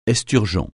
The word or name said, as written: esturgeon